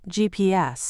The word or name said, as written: GPS